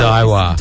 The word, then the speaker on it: Daiwa: Daiwa.